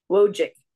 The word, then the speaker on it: Wojcik: Wojcik.